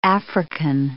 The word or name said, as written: African